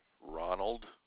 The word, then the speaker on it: Ronald: Ronald. R-O-N-A-L-D, Mailport.